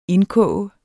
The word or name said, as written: indkoge